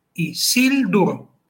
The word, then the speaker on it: Isildur: Por ejemplo, Legolas, pero Isildur. Como Isildur. Sil termina en consonante, en concreto en una L. Es fuerte y se lleva el acento.